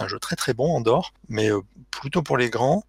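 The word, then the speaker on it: Andor: Andorre, comme on prononce.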